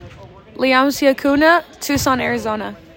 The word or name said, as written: Leamsi Acuna